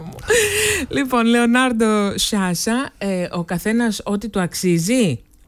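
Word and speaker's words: Sciascia: Λέγεται Λεωνάρντο Σάσα. – Σάσα. – Σάσα, έτσι όμως, με παχύς. – Είναι το έστω τελικό με το σι που διαβάζεται παχύ. – Ναι, ναι, ναι. Σάσα. Δεν είναι σιάκα ή αυτό.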